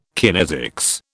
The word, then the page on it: kinesics: British English